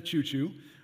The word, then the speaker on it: Ciuciu: It's called Prayers of Rest by Asherita Chuchu, and that is how you pronounce her name, although it's spelled C-I-U-C-I-U.